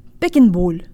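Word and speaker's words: Beckenbauer: Make sure to protrude your lips so you get a nice full O sound. Beckenbowl.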